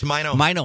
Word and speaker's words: Maino: He played the Mino card and M-A-I-N-O, and you know, pronounced Mino. – Mino.